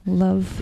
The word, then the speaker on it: Louv: Louv, I think. – Louv? – Love. – Yeah, L-O-U-V.